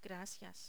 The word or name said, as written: gracias